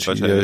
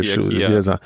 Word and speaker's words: Chia: Kia, Chia, wie, wie spricht man das eigentlich wirklich Chia, aus? Chia, hier, Chia.